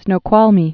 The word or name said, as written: Snoqualmie Falls